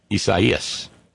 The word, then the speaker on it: Isaias: Isaias, I missed a syllable. Thank you, Flavio. Knower of words. Master knower of words, Flavio. Isaias.